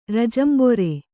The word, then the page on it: ರೋಜಾಂಬರಿ: rōjāmbari